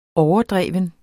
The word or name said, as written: overdreven